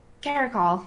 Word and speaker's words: caracal: Regal Caracal? – Oh, yeah, the Desert Cat. – That's how you pronounce it, right? Caracal? – Caracal.